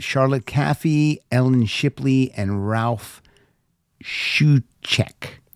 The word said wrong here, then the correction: Shuckett: Ralph Shuckett. That's what it says. Rhymes with fuck it.